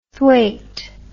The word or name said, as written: thwaite